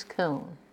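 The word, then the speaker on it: Koone: Marjorie Louise Kuhn, M-A-R-J-O-R-I-E, L-O-U-I-S-E, K-O-O-N-E.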